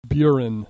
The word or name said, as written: burin